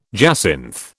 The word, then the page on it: jacinth: British English